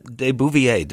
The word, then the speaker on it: de Beauvoir: Simone de Beauvoir. Did I pronounce that right? – De Beauvoir. De Beauvoir. – De Beauvoir. – Yes.